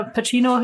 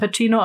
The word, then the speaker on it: Pacino: Ja, also ich, ich glaube El Pacino oder Pacino ähm, auf Italienisch spricht man es eben Pacino, auf Amerikanisch Pacino.